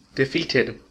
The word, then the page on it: defeated: American English